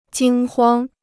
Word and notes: 惊慌: jīng huāng

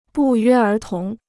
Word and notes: 不约而同: bù yuē ér tóng

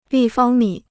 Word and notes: 立方米: lì fāng mǐ

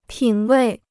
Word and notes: 品位: pǐn wèi

